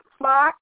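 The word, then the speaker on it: Phox: L-A, capital R-O-N-D-A, and my last name is Fox, but spelled P as in Peter, H-O-X,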